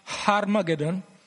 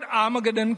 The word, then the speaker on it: Armageddon: Actually, in Hebrew it is pronounced Harmageddon, but in English the H has been taken out. And the word Armageddon comes from the Hebrew word Har Megiddo.